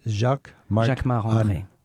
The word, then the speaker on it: Jacquemart-André: Jacquemart-André.